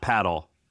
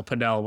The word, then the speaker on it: padel: It's like, it's not paddle. – Paddle, padel, whatever. Same thing. Same sport. – Well, I want to say it right. – It depends, like Latin America versus US. Either one is fine. It's spelled P-A-D-E-L, not P-A-D-D-L-E. So paddle, padel, whatever.